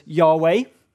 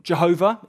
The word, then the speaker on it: Yahweh: We might try and put that into sounds that we can say in our language, which can be pronounced as Yahweh or Jehovah is the slightly more traditional way of saying it.